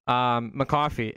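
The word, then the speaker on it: Muhcofy: So my name is literally McCoffee on all social medias, M-U-H-C-O-F-Y. I know phonetically it's Kofi, but it is said coffee.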